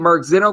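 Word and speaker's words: Mark Zinno: First and last name, Mark Zeno. Last name is spelled Z-I-N-N-O.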